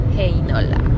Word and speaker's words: Heinola: Heinola. Porque tengo esa cosa ahora con el finlandés que la EI se pronuncia EI.